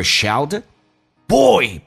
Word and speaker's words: shouted: His mother shouted,Boy, be careful, don't ride too fast.